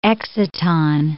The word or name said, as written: exciton